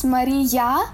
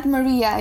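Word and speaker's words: Mariia: Yes, my full name is Maria Mylnik with two I's, because this is it is pronounced Maria, not Maria, you know, and that's why it has two I letters in it.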